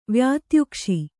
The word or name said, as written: ವ್ಯಾತ್ಯುಕ್ಷಿ